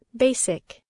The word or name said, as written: basic